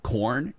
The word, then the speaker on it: Quorn: But also, if you want to check out something you can find at the grocery store, brother, is corn. It's spelled Q-U-O-R-N.